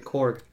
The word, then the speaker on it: Korg: But Krog, right? – Korg. – Korg.